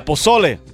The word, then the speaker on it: pozole: But have you ever tried pozole? Is that how you pronounce it? – Yeah, pozole.